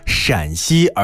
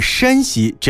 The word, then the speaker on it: 陕西: 母变化表示声调，韵母安的国语罗马字的四声标法依次为 AN ARN AAN 和 ANN，所以呢，山西就被译作是 SHANXI，山中多加一个 A 才表示陕西，就是我们刚读到的 SHANXI。 – 虽然说起来好像有点绕口啊，但是我们知道就是多加一个 A 就表示出了声调，那外国友人来到博物馆的时候就可以准确的读出陕西而不是山西这样的音了。